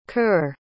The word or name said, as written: curr